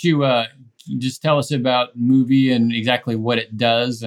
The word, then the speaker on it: Mouvi: is Samuel has a site that he created called Movie, although it is spelled differently, M-O-U-V-I.